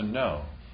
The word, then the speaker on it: know: We get kno, and then someone decides to drop the K. We still spell it that way, but to know.